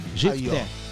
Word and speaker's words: Jefté: GFTE, ó, é um, se escreve assim, J E F mudo T I E. – Jefté. Facinho. – Fala GIFTE porque tem um acentinho no E, mas você não vai pôr acento aqui no.